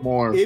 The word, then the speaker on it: Moore: Não, sem problema, é que ele é inglês, a pronúncia do More. – M-O-R-E em inglês seria More, mas é...